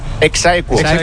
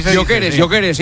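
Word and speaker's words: ex aequo: ¿Cómo se dice? – Exaequo. – Yo queres. Dice yo queres.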